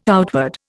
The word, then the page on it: outward: Indian